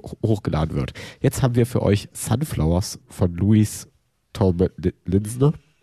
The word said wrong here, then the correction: Louis Tomlinson: Den Sonn? Wie wird's ausgesprochen? Kann mir jemand helfen? – Sunflowers von Louis Tomlinson.